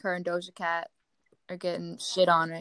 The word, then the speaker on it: Doja Cat: I think it's pronounced Doja Cat, right? Yeah.